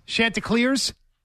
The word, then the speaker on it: Chanticleers: Chanticleers? – Yes.